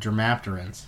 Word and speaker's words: Dermaptera: Or, if we're talking about the specific order, Dermapterans.